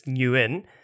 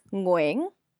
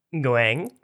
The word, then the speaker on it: Nguyen: Um, so, if, if, the the anglicized pronunciation is Nguyen, uh, uh, in Vietnamese it's pronounced Nguyen. – Nguyen.